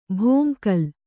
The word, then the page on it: ಭೋಂಕಲ್: kannada